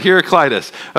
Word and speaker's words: Heraclitus: There was actually an Ephesian philosopher named Heraclitus. Rick, am I right? Yes, I got it right, Heraclitus. Okay, he was telling me beforehand how to pronounce it.